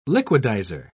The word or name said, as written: liquidizer